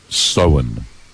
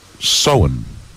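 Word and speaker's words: Samhain: Sowen. Okay, remember that, as in sewing your jeans, sewing a shirt, sewing a button. But it is actually, it's actually the Irish word for Halloween, spelled S-A-M-H-A-I-M. But it's pronounced sewing.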